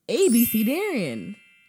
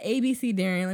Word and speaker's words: abecedarian: So, at ABC Darian, let me spell it up for you. ABECEDARIAN. It's a noun.